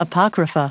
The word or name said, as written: apocrypha